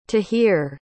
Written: tahir

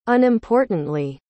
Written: unimportantly

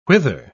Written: whither

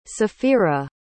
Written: saphira

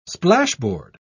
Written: splashboard

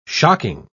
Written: shocking

